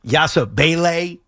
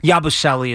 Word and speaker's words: Yabusele: By the way, I've gone back and forth on the correct pronunciation of this. Yasabele is what I've heard. – Yasabele? – But Yabusele is what I've heard. Yabu. – Yeah, what's called Yabusele is said by everybody.